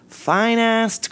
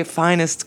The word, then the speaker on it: Finast: And it's an ad for fine-assed corn. It's spelled... – Fine-ass corn? – It's spelled F-I-N-A-S-T. – Fine-ass corn. – Not finest corn. Oh. I'm sure we're meant to say finest corn.